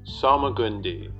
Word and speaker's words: Salmagundi: Uh, it's French. Didn't know that. Salmagundi. Salmagundi. That's how they say it. Salmagundi. Salmagundi. So they emphasize the. – Salmagundi. Salmagundi. How do you say it? Salmagundi. – Got you. – Okay. So they emphasize the gun part.